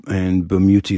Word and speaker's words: Bamuthi: Yeah, and the full name is Mark with a C, Mark Bamuti Joseph. And Bamuti is spelled BAMUTHI.